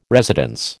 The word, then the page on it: residence: American English